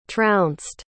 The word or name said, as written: trounced